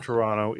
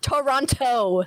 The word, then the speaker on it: Toronto: Toronto, say it right. – Say it like an American. – He's gonna kick me out of the office, guys. It's gonna happen. – So that no one pronounces the T's in Toronto.